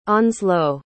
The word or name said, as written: onslowe